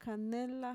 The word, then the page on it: kanela: Mixtec